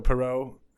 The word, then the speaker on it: Parrot: Yeah, and I guess somewhere along the way, the man that you were talking about, whose name is Jean-Claude Parrot, probably Perrot, but it's spelled like Parrot. So Perrot, who Ron quoted,